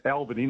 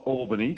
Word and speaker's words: Albany: It's pronounced Albany, not Albany.